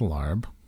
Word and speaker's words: larb: Larb. – It's larb.